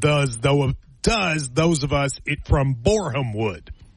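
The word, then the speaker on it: Borehamwood: Myron, we have a listener who is from Boreham Wood. – Oh, yeah? – They said, Matt, your pronunciation is off. It's Boreham, not Boreham.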